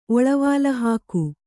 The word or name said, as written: ಒಳವಾಲಹಾಕು